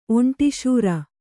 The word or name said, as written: ಒಂಟಿಶೂರ